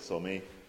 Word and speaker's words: Sommier: Sommier, or Sommier, it's written with an E acute, but pronounced by the locals as Sommier, is a small village.